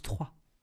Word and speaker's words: Troyes: Trois, il y a trois. La ville se prononce Troyes.